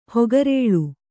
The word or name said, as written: ಹಕ್ಕೆರಲು